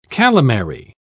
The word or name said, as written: calamari